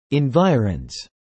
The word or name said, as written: environs